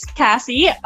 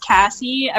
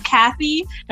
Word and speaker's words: Kasii: So hi, my name's Kasii. So every time I say my name's Kasii, everyone's like, oh, Kathy. No, it's Cassandra or Kasii.